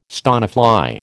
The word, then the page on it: stonefly: American English